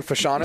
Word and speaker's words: Fashanu: Oddly enough, one of the top prospects, Fashanu, is that how you pronounce his name at Penn State? – Yeah. – Olu Fashanu.